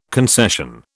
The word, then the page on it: concession: British English